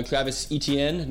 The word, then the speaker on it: Etienne: Okay, so Travis Etienne, not E-T-I-N. – Yeah. – For all you idiots out there that keep saying it wrong.